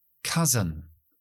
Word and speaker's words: cousin: Cousin. Cousin. Acento al principio. Dejo schwa en la segunda sílaba.